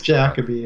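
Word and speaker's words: Jacobi: And I think it's Jacoby, right? I think I mispronounced his name just now. – It's Jacoby, yeah,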